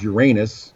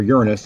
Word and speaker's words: Uranus: And then, of course, there is Uranus, or Uranus, depending on your pronunciation.